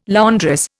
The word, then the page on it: laundress: Indian